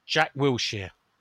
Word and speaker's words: Jack Wilshere: Can pronounce Jack Wilshere okay, or has he been practising? – Jack Wilshere. – Ah, see. Well done.